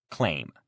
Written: claim

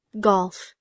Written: golf

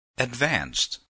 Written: advanced

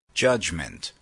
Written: judgment

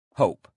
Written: hope

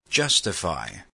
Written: justify

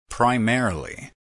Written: primarily

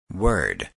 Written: word

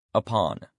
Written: upon

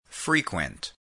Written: frequent

